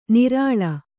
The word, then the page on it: ನರಳು: Kannada